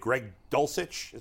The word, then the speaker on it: Greg Dulcich: Another guy who's become popular, getting back to tight ends here for a minute, Dave, Greg Dulcich, is that how you say his name? – Yes, that's how you say it.